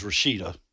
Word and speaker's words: Rashida: Well, first of all, her name's Rashida. – Yes. – He mispronounced her name mm-hmm. on several occasions.